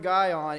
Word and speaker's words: Gihon: It was spelled G-I-H-O-N, Gihon, I believe is how it's pronounced.